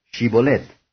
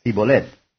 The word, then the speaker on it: Shibolet: Si él respondía: «No», entonces le decían: «Ahora pues, di Shibolet». Y él decía: «Sibolet», porque no podía pronunciar de aquella suerte.